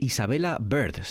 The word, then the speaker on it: Isabella Bird: Para hablar de Isabella Bird, se pronuncia.